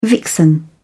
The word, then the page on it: vixen: British English